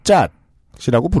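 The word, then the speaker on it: kyat: 통화는 왕년엔 차트 이렇게 표기했고 지금은 그냥 원어 발음대로 짜이라고 부릅니다.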